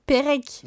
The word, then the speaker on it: Perec: Mais Marie-Jo, elle est française, donc Marie-Jo Perec.